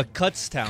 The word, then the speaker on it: Kutztown: Is it pronounced Kutztown or Cuts. Did you Kutztown? – Kutztown.